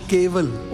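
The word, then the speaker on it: ਕੇਵਲ: Gobind Milan Ki Eh Teri Variya, Avar Kaaj Tera Kite Na Kaam, Mele Saad Sangat, Bhaj Keval Naam. The stress is on Keval, only Naam.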